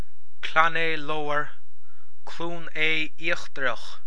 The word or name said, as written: Cluain Aodha Íochtarach